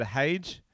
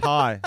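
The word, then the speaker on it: hej: I believe I said hage in Danish. – Hedge. Hi. – Oh, no, it's just pronounced high.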